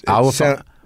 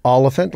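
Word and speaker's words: Olyphant: No, it's not, but it's Oliphant. – Oliphant. – Oliphant, like elephant.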